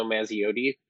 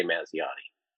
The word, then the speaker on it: Mazziotti: Yeah, my last name's Italian, also Mazziotti, but most people say Mazziotti.